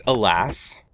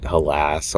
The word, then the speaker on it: Helas: Yeah, people pronounce it halas or helas or whatever, but it's alas. It's the anachronistic spelling of alas.